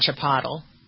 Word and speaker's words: chipotle: It's a Spanish word. It's like saying something wrong in French. – And a lot of people call the restaurant chipotle.